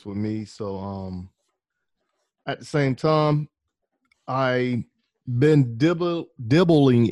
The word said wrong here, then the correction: dabbling: in, I don't even know if I'm saying that right. Well, I can say know. Playing around with stage. – Dabbling. Okay.